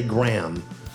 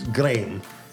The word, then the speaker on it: Graeme: I think here in the United States we might say Graham, but I think it's Graeme, like frame.